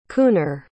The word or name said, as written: cooner